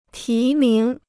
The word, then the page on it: 提名: tí míng